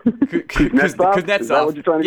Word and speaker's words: Kuznetsov: Kuznetsov. – Yeah, Kuznetsov.